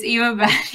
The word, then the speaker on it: Eva Benn: It's Eva Benn.